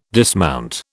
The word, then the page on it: dismount: British English